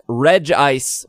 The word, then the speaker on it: Regice: And then finally, Rock, Regice, which I verified, that's how you say it.